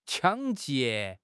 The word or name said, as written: 抢劫